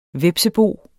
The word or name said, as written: hvepsebo